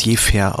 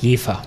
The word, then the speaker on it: Jever: Also man spricht Jever eigentlich als Jefer aus. Jefer. Hm, Jefer, genau. Jefer.